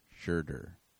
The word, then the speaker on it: Schroeder: Oh, Schrader? Schroeder? – It's Schrader, isn't it? It's pronounced the last vowel. – Schroeder. – Schroeder? – Schroeder. – Jesus Christ. – An ER is in Fern. Schroeder.